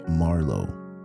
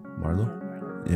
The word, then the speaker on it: Marleau: We have the latest single by Jubb, Mr. Stee, and Marlo. That's how you say it, Marlo, right? M-A-R-L-E-A-U. Marlo? Yeah.